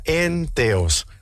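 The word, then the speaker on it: enteos (ἔνθεος): Esta palabra en el griego se pronuncia enteos, que significa en Dios.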